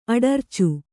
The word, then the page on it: ಅಡರಿಚು: aḍaricu